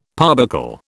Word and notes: parbuckle: British English

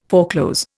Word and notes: foreclose: Indian

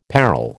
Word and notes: parol: American English

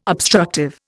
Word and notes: obstructive: Indian

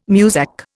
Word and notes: Muzak: Indian

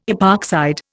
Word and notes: epoxide: Indian

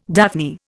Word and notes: daphne: Indian

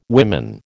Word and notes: women: American English

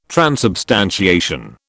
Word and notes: transubstantiation: British English

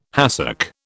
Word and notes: hassock: British English

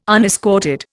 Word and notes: unescorted: Indian

